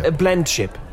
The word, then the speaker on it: blendship: Blemship. Am I saying it right? Blemship. – Blendship. Blendship. It's the perfect blendship.